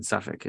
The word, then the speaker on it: Suffolk: I'm not sure, but I do know that with the L is silent in Suffolk. It's just okay. Suffolk.